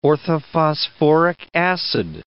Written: orthophosphoric acid

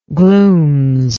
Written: glooms

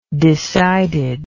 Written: decided

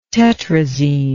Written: tetrazene

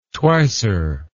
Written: twicer

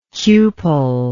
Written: cupel

